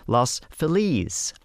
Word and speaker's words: Los Feliz: Mark Andrada said, I went to Los Feliz Elementary in 1978 as a fifth grader, and both the kids and staff pronounce it exactly like it is in Spanish, Los Feliz.